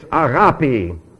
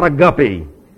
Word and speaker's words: agapi: It's agapi, or agapao, not a guppy.